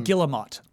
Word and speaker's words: Guillemot: Eve Guillemot. – Is that Gilmo? – Guillemot.